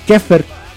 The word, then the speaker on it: Xepher: Se sigue pronunciando igual, se pronuncia kefer pero se escribe con X en vez de